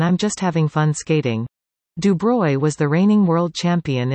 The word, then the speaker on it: Dubreuil: Dubreuil, pronounced Dubreuil, races that distance Sunday in Calgary.